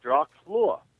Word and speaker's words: Dracula: But he heard stories about Dracula, the real historical Dracula, and he heard the name, and the name sounded like Dracula. Dracula was what he heard, which is the Romanian pronunciation of the name, Dracul, meaning dragon, and Ya, meaning son of.